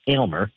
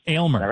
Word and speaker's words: Aylmer: My pronunciation of it, I think I finally have it right. Aylmer. – Aylmer. – Right? – Yeah, that's right.